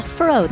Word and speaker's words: Foroux: How do you pronounce this? Faroe? Faroe? You think it's Faroe? – Yeah, Faroe. That seems like it checks out. – F-O-R-O-U-X.